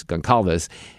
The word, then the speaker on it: Goncalves: It's a little hard to pronounce her name. It's like Gonzalez, but it's Goncalves.